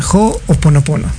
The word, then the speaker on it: Ho'oponopono: Así que, pues, vamos a hablar un poquito de Hoponopono. Lo acabas de mencionar muy bien. Sí, se pronuncia Ho'oponopono.